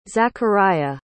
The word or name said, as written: zachariah